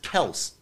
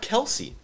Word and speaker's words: Kelce: And he said his last name is actually pronounced Kels, not Kelce, which everyone calls him Travis Kelce.